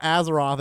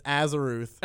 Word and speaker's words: Azeroth: It's not Azeroth, it's Azeroth.